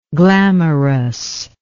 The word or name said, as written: glamorousness